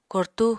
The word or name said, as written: kortú